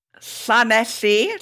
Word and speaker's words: Llanelli: Well, congratulations on the pronunciation, boyo. Get that tongue up in the roof of your mouth. – I watched Ivor the Engine as a kid. – Llanelli, Llanelli Bach.